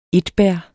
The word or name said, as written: etbær